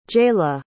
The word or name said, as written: jaylah